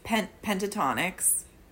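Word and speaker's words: Pentatonix: And also it's pentatonics.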